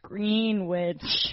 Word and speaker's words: Greenwich: And it's pronounced Greenwich.